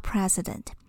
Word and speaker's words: precedent: 它的发音跟总统是一样的，但是要注意它的拼法并不一样哦。 An epidemic on this scale is without precedent.